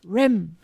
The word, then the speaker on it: Roehm: I don't know how you pronounce the last name. Rem. It's pronounced R-O-E-H-M. Rem. – Thank you. – Rum. Mark Rum. That's how I would say it. It's Mark Rum. – You said it was pronounced R-O-E-H-M.